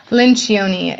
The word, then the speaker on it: Lencioni: And then the third thing you mentioned was, I'm gonna get his last name wrong, but Patrick Marchione? – Lencioni. It's an L. – Okay. – Yes. It's Italian.